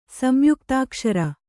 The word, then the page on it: ಸಂಯುಕ್ತಾಕ್ಷರ: kannada